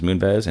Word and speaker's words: Moonves: One, apparently that is his pronunciation of his name is Moonves, and two, he is out.